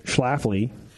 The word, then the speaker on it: Schlafly: S-C-H-L-A-F-L-Y.